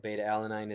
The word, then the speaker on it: beta-alanine: Beta alanine? Did I say that correctly? – Yes, beta-alanine. It is,